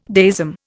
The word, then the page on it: deism: Indian